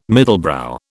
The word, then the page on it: middlebrow: British English